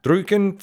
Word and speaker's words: Drücken: I had Zehen for pull, Drücken for push.